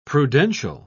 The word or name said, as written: prudential